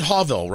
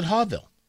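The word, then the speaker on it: Hughville: I, I, I pronounce it Hawville, right? H U G H V I L L E. I pronounce it Hawville.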